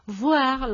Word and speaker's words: voir: Boire con una pronunciación oclusiva sonora, B, bilabial, B, porque si lo hacemos labiodental con la V, V, sería voir, lo que significaría ver.